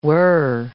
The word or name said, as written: whirr